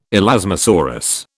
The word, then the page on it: elasmosaurus: British English